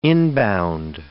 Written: inbound

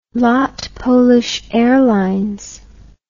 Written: lot polish airlines

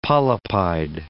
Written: polypide

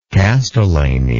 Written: castellany

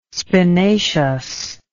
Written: spinaceous

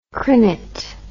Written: crinet

